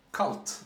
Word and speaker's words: CVLTE: Kalt. – Ja. – Som vi, det tydligen uttalas. C-V-L-T-E.